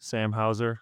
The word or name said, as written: Sam Hauser